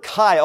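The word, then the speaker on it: Kai (καί): In the Greek language, the word for and, A-N-D, Looks like Kai, K-I, K-A-I, pronounced Kai.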